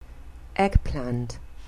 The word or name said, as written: eggplant